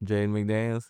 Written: jaden MCDANIELS

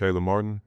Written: Caleb Martin

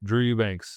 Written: Drew Eubanks

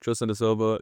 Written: Tristan Da Silva

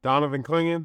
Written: Donovan Clingan

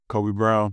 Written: Kobe Brown

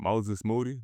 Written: Moses Moody

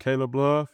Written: Caleb Love

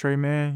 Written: Tre Mann

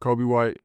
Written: Coby White

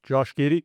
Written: Josh Giddey

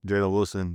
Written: Jalen Wilson

